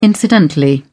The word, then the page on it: incidentally: British English